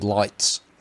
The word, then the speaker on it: Leitz: Well, how do you pronounce when you say LEITZ? Is it lights or leets? Or I go with lights.